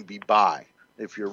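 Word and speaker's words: Bayh: Now Missouri, I hear that Evan Bay, is that how you say his name? – Yeah, is favored over the Rep.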